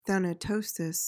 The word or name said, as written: thanatosis